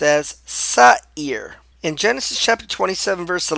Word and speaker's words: Seir: And it's pronounced in Hebrew, Seir.